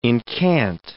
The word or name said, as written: incant